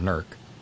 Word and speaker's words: Newark: That's N-E-W-A-R-K. I had a fraternity brother from Newark, and I can verify the fact that it is pronounced Newark.